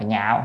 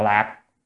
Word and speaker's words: nhạo: chữ này đọc là nhạo không đọc là lạc, nhạo mới yêu thích đó.